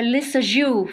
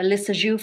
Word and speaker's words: Lissajous: I'm sorry, no way, it's spelled L-I-S-S-A-J-O-U-S. – Oh, a Lissajou figure. It's like based on the Lissajou figure. – Okay. – It's a French name of a French scientist. He found out a way to visualize a sound wave. So the figure is named after him, Lissajou. – Okay, got it. – That's how you pronounce it.